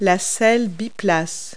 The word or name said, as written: dual seat